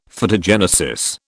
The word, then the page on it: Phytogenesis: British English